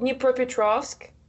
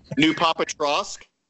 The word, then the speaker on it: Dnipropetrovsk: It was called Dnipropetrovsk. I don't know if you guys want to try to pronounce it. Everybody, when they read my... – Dnipropetrovsk? – There you go.